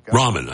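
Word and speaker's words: Raman: Nithia Ramon. Ramon, I mean. Ramon. Oh, like Top Ramen. Like ramen noodles.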